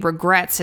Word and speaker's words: Regrettes: And I remember thinking Regrets is such a great name because it's The Regrets, but it's spelled E-T-T-E-S, like kind of a 60s girl group vibe.